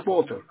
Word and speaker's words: Walter: My name is Walter. – It's Walter? – Yes. – How do you spell that? – W-A-L-T-E-R.